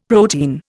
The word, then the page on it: protein: Indian